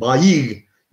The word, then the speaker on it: bayi: Bayi, bayinin arkasında bir tane ayn var. Bayi o yüzden. Çünkü arada şey gibi düşün bunu. Sanki bir yumuşak G varmış arada gibi düşün. Bayi gibi düşün o kelimeyi.